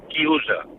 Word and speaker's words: chiusa: Y un momento, y quiero decirle a Fernando italiano se dice chiusa.